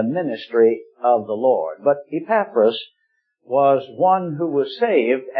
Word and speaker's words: Epaphras: Some might pronounce his name Epaphras, but Epaphras was one who was saved, and quite evidently he was one of the key founders of this local church there.